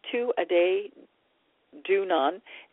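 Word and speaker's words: Tuatha Dé Danann: Tuatha De Danann, pronounced Tu-a-day Dunan, is translated as the tribe of Danu.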